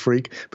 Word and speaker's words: Freke: It is actually pronounced freak, but it's spelt F-R-E-K-E.